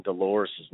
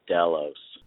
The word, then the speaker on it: Dolores: Hey, uh, you guys are pronouncing Dolores' name wrong. The R and the E are silent. It's Delos.